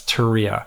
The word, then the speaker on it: Turia: Turia, I think I'm saying it right. Yeah, it's Turia.